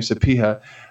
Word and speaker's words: Sapieha: He gave Sapieha, or how we say his name, Sapieha.